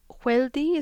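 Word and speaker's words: Hwéeldi: The long walk, the kind of Navajo equivalent of the Trail of Tears, the word for it in the book is Hueldi, is that how you say it? Something like that? – That's how I say it.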